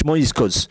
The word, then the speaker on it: Moriscos: but he defined it as or he used it as Moriscos, the same way it is pronounced in Spanish, Moriscos.